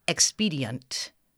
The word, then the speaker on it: expedient: So if they think I need to pronounce the word expedient, because it's more common to say expedient. You're not saying ent necessarily. You're saying ent. It's going to have a schwa in there.